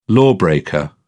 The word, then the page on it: lawbreaker: British English